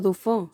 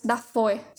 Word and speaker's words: Dafoe: Yo le digo Dufo. – Pero es Dafoe.